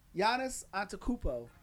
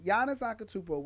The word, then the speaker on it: Giannis Antetokounmpo: Giannis Antetokounmpo. It took me like two years to figure out how to pronounce his pronounce his name, but Giannis Antetokounmpo wins back to back MVPs.